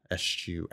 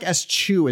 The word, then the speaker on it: eschew: Eschew. It looks like eschew is also an acceptable pronunciation. – Oh, okay. – Eschew. Yeah, eschew or eschew.